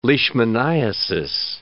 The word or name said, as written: leishmaniasis